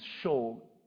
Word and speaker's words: shew: And the word that's used is show It's spelled S-H-E-W.